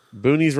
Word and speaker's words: boonies: Speaking of boonies, boonies rhymes with goonies.